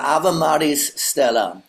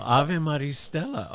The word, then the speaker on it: Ave Maris Stella: Oh, it's not Maria, actually. It's Ave Maris? – Close enough. – How do you say it? – Yeah, Ave Maris Stella. – Oh, Ave Maris Stella.